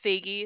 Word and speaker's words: Faigy: My name is Faigy. It's spelled F like Frank, A-I-G-Y.